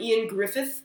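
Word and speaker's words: Ioan Gruffudd: I saw these commercials and starring Ian Griffith, whose name is spelled Eowyn Gruffudd, but he's Welsh, so none of his syllables make any sense. – Right. – So letters don't mean things in Welsh.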